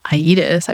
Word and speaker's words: Aedes: The new 80s scent. 80s, A-E-D-E-S. – Not that it's a pronunciation podcast, but I do know how to pronounce it. – Is that it? – It's Aedes.